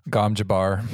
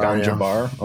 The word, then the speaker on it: Gom Jabbar: He does so, and then she puts a little needle at his neck, which is called the Gom Jabber, unless you guys want to pronounce it differently. – Gom Jabbar. – Jabbar. Gom Jabbar.